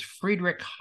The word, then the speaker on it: Friedrich: By the way, it's not Frederick. It's Friedrich Hayek, and it's spelled H-A-Y-E-K.